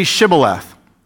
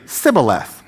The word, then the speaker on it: Shibboleth: And he would say, Sibboleth. True story here. For he could not pronounce it right. So there's something in their dialect that just, they couldn't get the sh. It could only be the s.